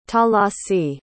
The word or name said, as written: talasi